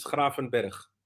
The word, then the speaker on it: Gravenberch: But Ryan Gravenberch, is that right? – Probably in English it's right. – Go on, then you say it. – In Dutch it's Gravenberch.